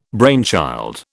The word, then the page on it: brainchild: British English